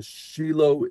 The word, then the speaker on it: Shiloh: And to help the Shiloh Israel, here I'm using the Hebrew pronunciation, but it's spelt like Shiloh,